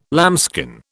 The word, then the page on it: lambskin: British English